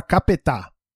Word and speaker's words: Capeta: É, chama Capetá. Se escreve capeta.